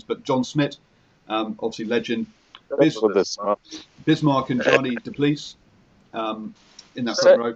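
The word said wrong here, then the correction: Jannie du Plessis: Say again? Sorry, Bismarck and Johnny DePlease. Anyway. – Yanni Duplessis. – Yanni Duplessis.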